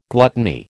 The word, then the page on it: gluttony: American English